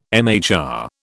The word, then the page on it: MHR: British English